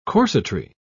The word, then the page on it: corsetry: British English